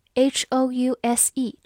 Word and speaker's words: house: 最后，请大家注意对比 h o u s e 这个词。它读作 house，o u 字母组合发 ow 这个双元音 house，它是名词，表示房屋或者房子。